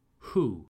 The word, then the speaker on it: who: So I hear wool and woo. Sorry, guys, the W here is silent.